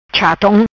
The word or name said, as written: Chatom